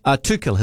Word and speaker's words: Tuchel: Tuchel, his surname is pronounced.